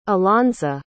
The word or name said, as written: alonza